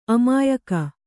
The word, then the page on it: ಅಮಾಯಕ: amāyaka